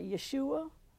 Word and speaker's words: Yeshua: Jesus in the Hebrew word, I guess they pronounce it Yeshua, J-E-S-H-U-A.